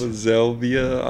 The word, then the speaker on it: Zelvia: Zelvia. I don't even. It's almost like salvia, but with a Z.